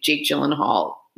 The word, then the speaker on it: Jake Gyllenhaal: Let us know if you think Jake Gyllenhaal needs to give her back a scarf.